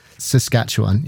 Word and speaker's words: Saskatchewan: Just say Sask. I'm going to have to write it phonetically in front of me. Saskatchewan. Yeah, that's it.